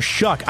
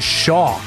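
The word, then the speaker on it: Shough: I had said it as Shaw, but it's Shuck. And it doesn't look like that it should be Shuck, like Chuck, but it's Shuck is the way that you pronounce Tyler.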